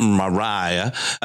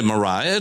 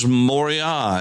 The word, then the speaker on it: Moriah: It's not Moriah. And that's okay to say Moriah. It's okay to say that. That's the, we place the long I there. But the Hebrew word is Moriah. And the reason is because Moriah is a compound word. Ra'ah means to see. It's R-A-A-H, Ra'ah.